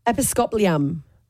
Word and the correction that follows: Episcopalian: Episcopalian. This is the actual pronunciation.